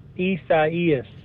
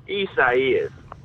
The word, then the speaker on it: Isaias: Isaias. – So Cody knows how to pronounce it. Let's see. Tim Dog knows how to pronounce it. – Isaias.